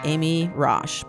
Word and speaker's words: Amy Raasch: This music is by our wonderful friend, Amy Roche.